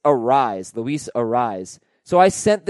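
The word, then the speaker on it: Arraez: He pronounces it Arise, no S sound on the end like Luis Arias.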